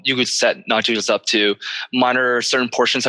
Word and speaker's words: Nagios: What about Nagios? And I don't know if I'm pronouncing that right. – No, you're saying it right.